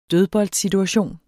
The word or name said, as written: dødboldsituation